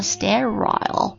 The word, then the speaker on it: sterile: S-T-E-R-I-L-E 它有两种读音呢英式的话 sterile